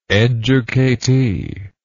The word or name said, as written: educatee